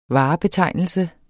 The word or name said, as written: varebetegnelse